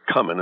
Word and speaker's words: cumin: The traditional pronunciation is cumin. In fact, sometimes has been spelled with two M's.